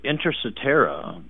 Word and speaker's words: Inter cetera: And that's spelled I-N-T-E-R, I-N-T-E-R, inter. – I-N-T-E-R. – T-E-R. And then the second word is C-E-T-E-R-A. C-E-T-E-R-A. – R-A. Enterera. – Intercetera. Like et cetera.